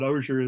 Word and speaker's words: Ozier: Yeah, it's been mispronounced a lot more often than it's been pronounced correctly, but Ozier is fine. – Ozier, okay. O-Z-I-E-R.